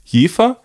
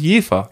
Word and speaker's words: Jever: Aber ich muss noch kurz, bevor jemand sagt, das wird falsch ausgesprochen, wir sagen Jefer, Jever. Gut, jetzt sind beide zufrieden, oder? – Und wie wird es denn richtig ausgesprochen? – Ich glaube, ich habe im Zivildienst mit jemandem zusammengearbeitet, die kam aus Jefer. Und deswegen hat sie immer das heißt Jefer.